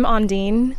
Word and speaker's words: Ondine: I'm Ondine. – Ondine, how do I spell that? – O-N-D-I-N-E. – O-N-D-I-N-E.